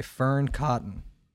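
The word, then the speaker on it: Fearne Cotton: Could you be more authentically you by Fern Cotton? That pronounced correct? – Yeah, nailed it.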